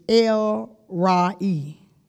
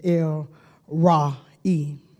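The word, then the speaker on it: El Roi: And I want to make sure I say it right. The God who sees is El Ra'i. That's how, now they gave it a long beard, but when I seen it shorter, it was El Ra'i.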